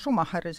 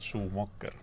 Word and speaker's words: Schumacher: -hmm no võib-olla lihtsalt selgituseks, et eh, Schumacher oli, eh, hääldatakse vist Schumacher mm, -hmm.